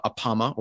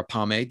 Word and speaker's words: Apama: apama or apame depending on your pronunciation